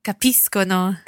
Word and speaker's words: capiscono: That's for the they form, the third person plural, which will be capiscono. – Capiscono. And of course, it's being followed by an O there. So capiscono. It's a hard C sound.